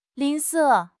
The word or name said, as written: 吝啬